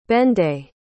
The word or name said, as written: benday